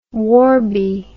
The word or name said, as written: warby